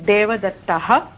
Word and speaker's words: देवदत्तः: देव न देवदत्त न्यायवादी न देवदत्तः विसर्गः तस्य उच्चारण सम्यक्तया भवतु यु नीड् टु प्रोनौन्स् द विसर्ग पर्फेक्ट्लि यु केनाट् काम्प्रमैज़् देवदत्तः वदतु महोदय